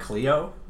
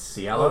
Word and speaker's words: Cielo: It's not Cleo, it's Cielo.